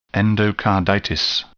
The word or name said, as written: endocarditis